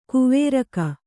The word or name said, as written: ಕವರಗಿ